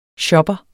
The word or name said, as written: shopper